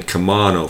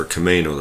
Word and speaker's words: Camano: Veronica kept saying Camano for Camano the first year, but tomato, potato, who cares? One of the recent candidates for Island County Commissioner pronounced it Camano too.